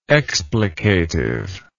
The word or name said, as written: explicatively